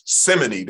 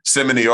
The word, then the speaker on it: simony: We saw Peter rebuke Simon in his simony that he was committing. His simony, either way, you can pronounce it both ways. Mm-hmm. Simony or simony.